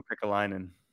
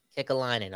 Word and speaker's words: Kekäläinen: Yeah, Jarmo Kekäläinen. – Kekäläinen, okay.